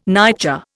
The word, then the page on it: nightjar: Indian